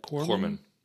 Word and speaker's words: corpsman: Corpsman.